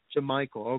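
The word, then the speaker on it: Jamycal: Yeah, I think it's just Jamichael. – Jamichael, okay.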